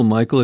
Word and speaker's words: Mycle: If you want to Google Michael, it's easy because his first name is spelled M-Y-C-L-E.